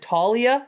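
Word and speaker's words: Taulia: What is it, Talia? Is that how you pronounce it? – Yeah, I believe so.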